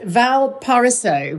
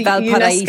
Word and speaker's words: Valparaiso: Valparaiso, which is, it's a Valparaiso. – UNESCO. That's it. Oh, well done.